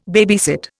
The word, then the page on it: babysit: Indian